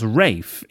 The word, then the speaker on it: Ralph: Cousin Ralphie, or Ralph, and then Hyacinth says, oh, they need to get a roof rack from Cousin Rafe, which, of course, Rafe is the posh pronunciation of Ralph, such as the actor Rafe Fiennes.